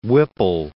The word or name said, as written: Whipple